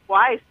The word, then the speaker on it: Weiss: Weiss, W-E-I-S-S.